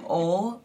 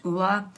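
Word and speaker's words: Ole: Um, and then for uh, moisturizer, I use the Rejuvenique oil and this brand called Ula Henriksen. I know it looks like Ol, but it's pronounced Ula.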